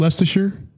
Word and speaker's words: Leicestershire: As you would say, I believe it's pronounced Leicestershire.